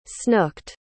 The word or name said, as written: snooked